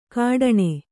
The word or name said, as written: ಕಠಾಣೆ